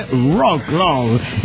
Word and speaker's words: Wrocław: Dice, hola amigos de la nave, les escribo desde la hermosa y siempre lluviosa ciudad de Wrocław, que por alguna razón se pronuncia Wrocław.